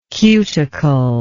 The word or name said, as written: cuticular